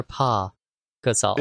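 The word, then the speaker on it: Pau Gasol: Paul Gasol, Tony Parker. – Tony Parker. I think Tony Parker will make it easily. But no, just is it Paul or Pa Gasol? – It's spelled P A U.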